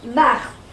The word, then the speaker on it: Mart: My name is pronounced Mart, M-A-R-T.